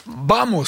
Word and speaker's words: vamos: Vamos, ça se prononce comme ça. – Tout à fait. – Le V se prononce B en espagnol, je ne parle pas espagnol.